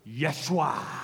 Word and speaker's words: Yeshua: But we're going to go on a journey with Yeshua. That's how you say it in Hebrew. Okay, Yeshua.